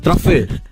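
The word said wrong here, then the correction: Truffaut: Non si dice così? – Truffer. – Vabbè, che hai detto? Truffer ha detto. – Ha detto truffé. Truffé.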